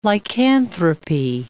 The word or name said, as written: lycanthropy